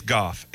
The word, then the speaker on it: Goff: Another texture uh, banging on me for the way I say G O L F. Look, it's it's Goff. It's always been Goff. It's always going to be Goff. I, I don't pronounce the L. There's Listen, no there's no L's in Goff.